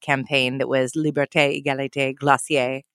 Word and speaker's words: Glossier: And now help me pronounce this company's name. – Glossier. – Glossier. – Yeah, it's a little bit fake French.